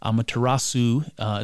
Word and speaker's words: Amaterasu: I'm going to mispronounce it again. Amaterasu is her name.